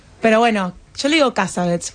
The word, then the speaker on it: Cassavetes: ¿Cómo se pronuncia Cassavetes en Estados Unidos? – Cassavetes. – Exactamente.